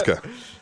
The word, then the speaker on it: Asuka: For the love of God, it's Asuka, not Asuka. It's a soft... – it's a soft it's Asuka.